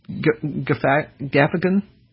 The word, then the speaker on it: Gaffigan: Dennis Habert, Jim, is it pronounced Gaffigan? I don't know how you pronounce his name. I see it all the time. – Yep. – Okay, so I got it right.